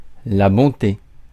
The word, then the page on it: bonté: France; [bɔ̃.te]; French